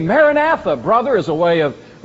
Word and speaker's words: Maranatha: It was literally pronounced Maranatha, which says, O Lord, come, in the Aramaic language. But we tend to say it Maranatha.